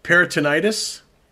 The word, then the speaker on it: peritonitis: The results of the inquiry so far indicate that Sorensen had abdominal peritonitis, which is the infection of the abdominal lining.